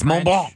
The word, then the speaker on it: Mont Blanc: France's tallest mountain is shrinking, Mount Blanc. Am I saying that right? – Mm-hmm. Mm-hmm. Yes. – Yep. – The tallest mountain in France. – Why'd you ask? – Well, because he's basically It's Mont Blanc.